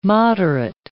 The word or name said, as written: moderate